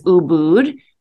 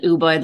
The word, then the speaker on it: Ubud: So I think the official pronunciation is Ubud, but a lot of people call it Ubud, like I call it Ubud. So either or.